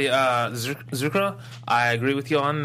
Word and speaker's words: Zurcaroh: But if I would say Shin, I would say Zucchera. Is that how you say it? – Zucchera.